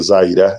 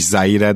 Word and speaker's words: Zaire: Úgyhogy ja, ez egy érdekes dolog lesz ez a 10-17 narratíva a szezon közben is nyilván és zai, Zai, tehát Z-A-I-re, így van, tehát valahogy úgy mondják egyébként, hogy, hogy nem is Zaire, de Zaire, valami ilyesmi, ahogy ezt kiejtik végül.